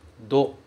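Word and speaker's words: do (土): Mas o que eu mais quero falar sobre esse kanji é outra pronúncia, que é a pronúncia do. Deixa eu colocar aqui de novo, kanji. Tsi. Aqui ele é pronunciado do. E ele significa aqui sábado. Então, quando ele significar sábado, ele vai ser pronunciado do.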